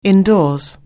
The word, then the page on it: indoors: anglais